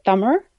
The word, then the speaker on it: Tamil: The correct pronunciation is actually Thummer, but it's too hard because there's a sound at the end of the word that is not found in the English language.